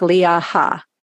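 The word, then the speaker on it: Leaha: It's L-E-A-H-A, like Leah Ha, Mattinson, M-A-T-T-I-N-S-O-N.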